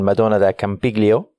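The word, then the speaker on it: Madonna di Campiglio: And we're going to talk a little bit about some of the stuff that we learned yesterday here in Madonna del Campiglio. Better pronunciation, Dave, than on the note show? – Fantastic. Genuinely almost perfect.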